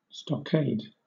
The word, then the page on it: stockade: British English